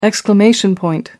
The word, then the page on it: exclamation point: British English